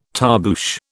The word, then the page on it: tarboosh: British English